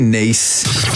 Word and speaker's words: Nice: Yeah, a Nice biscuit pronounced Nice like the name of the French city.